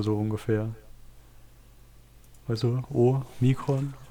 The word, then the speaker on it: Omikron: Genau, ein Omega ist ein langes O und ein Omikron ist ein kurzes O.